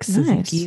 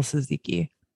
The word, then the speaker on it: tzatziki: Oh, like tzatziki, nice. Like real tzatziki. – Oh, that's like how you pronounce it. Tzatziki.